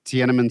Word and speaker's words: Tiananmen: Did I say that right? – Tiananmen Square. – Tiananmen Square? – Yes, with those big LED screens? – Oh, yeah.